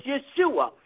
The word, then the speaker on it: Yeshua: His name is Yeshua. Hallelujah. That is the Hebrew way of pronouncing his name.